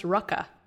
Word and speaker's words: RVCA: I mean, because that's like RVCA is actually pronounced Rucka. – What? Excuse you? – Yeah. Isn't that crazy? I've always been I love RVCA. Nope. It's Rucka. It's a U, yeah.